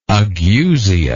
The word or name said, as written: ageusia